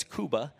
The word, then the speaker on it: Cuba: It's not Cuba, it's Cuba. I was corrected by the Cubans in our church.